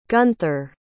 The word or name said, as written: gunther